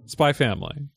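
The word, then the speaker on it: SPY×FAMILY: Spy Family. – Spy X Family. – It is spelled Spy X Family. – Yes. – It is pronounced Spy Family. – The X is silent.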